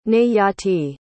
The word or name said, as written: nayati